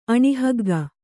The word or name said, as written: ಅಣಿಹಗ್ಗ